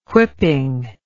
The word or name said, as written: whipping